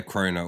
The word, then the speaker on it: krona: Wait, so how do you say it? Um your currency is krona? – Uh yeah, krona or or crowns either.